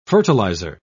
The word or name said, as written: fertilizer